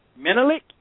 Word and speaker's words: Menelik: C-O-R-D-E-L-L-E. Corderell. Corderell Patterson. And there was a name I couldn't even pronounce. It's M-E-N as in Nancy, E-L-I-K. Menelik Wilson.